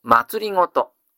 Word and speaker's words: 政: D の 15 番、政治の政の字を祭りごとと読みます。